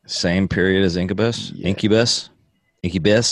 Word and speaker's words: Incubus: I keep saying that wrong. Incubus. – That's right. – Incubus, right? – Oh, Incubus. – Incubus. – Like incubator.